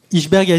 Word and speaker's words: Ijsberg: Tu es directeur de la publication et cofondateur d'Iceberg, d'Ichsberg, ça s'écrit I-J-S-B-E-R-G, Iceberg Magazine, comment vous dites ? – C'est une prononciation libre, c'est ce qu'on a voulu, c'est que chacun puisse approprier le nom et puisse le prononcer comme il le souhaite.